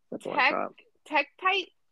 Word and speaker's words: tektite: It's a form of, I'm gonna butcher this, Tekiti. Is that what tekkenite. – It is? – It's probably tekkenite or something. – Tektite? Yeah.